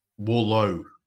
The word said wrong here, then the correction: Wardlow: Is how you say it? – Wardlow. – Wardlow. Wardlow. Yeah.